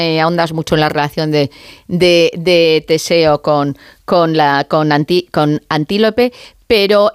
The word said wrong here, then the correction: Antíope: me gustaría, no sé, lo he dicho mal el nombre, es Antíope. – Antíope, ¿verdad? – Antíope, no, Antíope, no, que la he llamado yo como un animal y no puede ser, Antíope.